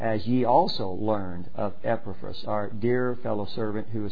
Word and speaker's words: Epaphras: Since we heard, is it, how do you pronounce his name? – Yeah, verse 7. Epaphras. See that? – Epaphras, yeah.